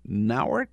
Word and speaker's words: Nauert: The latest is, I think, Nauert is how you pronounce her name, Heather Nauert, who is the press secretary at the State Department.